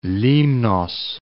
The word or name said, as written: Limnos